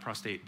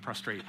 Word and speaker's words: prostrate: I actually lay out prostrate before God. Did I say that right? Prostrate, not prostate, prostrate.